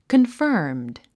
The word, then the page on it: confirmed: kənfə’ːrmd; コンファームド